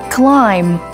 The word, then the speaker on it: climb: Psychology, tâm lý học, đánh vần là P-S-Y-C-H-O-L-O-G-Y. Trong khi đó, cần lưu ý rằng âm B là một âm câm khi nó đứng cuối từ và đứng trước nó là âm M. Ví dụ climb, crumb, dumb, calm.